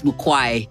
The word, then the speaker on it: Mukwae: That's not my name. My name is Mukwae. – Can you say it properly? It's Mukwae.